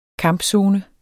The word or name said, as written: kampzone